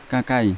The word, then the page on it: kakai: Yawa